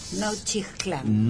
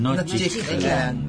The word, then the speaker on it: Nochixtlán: La X se pronuncia J, Nochixtlán. – Nochixtlán, gracias, gracias, ahí está, tiene usted razón, Oaxaca.